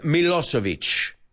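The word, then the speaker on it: Milošević: So, Slobodan Milosevic. This is how I learned to pronounce Modric, Djokovic, right? Because, ves, buscamos patrones. Sé que esa C no es... Es ch.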